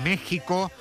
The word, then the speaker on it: México: Ayer vimos en directo la inauguración de este Mundial en el Estadio Azteca, que recordamos porque lo vemos venir, está en México, no en México. Se escribe con X, pero se pronuncia con J, ese país que no existió hasta que llegaron los españoles, según Ayuso.